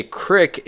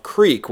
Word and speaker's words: creek: But the other thing I want to point out is a crick is the incorrect pronunciation of creek, which is a small body of water that flows.